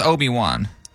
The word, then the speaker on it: Obi-Wan: So it's Obi-Wan, okay.